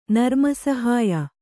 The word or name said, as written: ನರ್ಮಸಹಾಯ